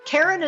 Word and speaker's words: Karen: Karen is spelled the normal way, no goofy Y's or Q's, K-A-R-E-N.